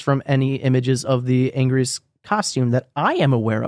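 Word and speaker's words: Anguirus: I believe it's Angrius. – Angurius. – It's spelled Angurius, but everyone says Angrius if I remember correctly.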